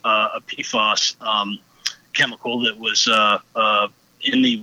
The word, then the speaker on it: PFAS: but the acronym is it PFAS? – PFAS. Yep. – PFAS.